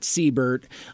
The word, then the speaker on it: Seibert: And, you know, I've called the show Mike Seibert Radio after myself. But the spelling is challenging. You know, it's S-E-I-B-E-R-T. Most people pronounce it Seibert. In fact, my name brother, Matt Seibert, out of who hosts the Mr. BS Show podcast out of Colorado.